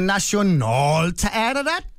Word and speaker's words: Nationalteateret: Nu skal I høre, hvordan man udtaler Nationalteateret på norsk, ikke? Der siger man Nationalteateret, ikke? Så man kører midt i ordet, der kører man sådan ned, og så kører man op til sidst, ikke? – Nationalteateret.